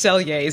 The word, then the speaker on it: Selye: This, Hans Selye, and I actually have spent a lot of my career trying to figure out, with all due respect, how to say his last name appropriately, but it's Selye.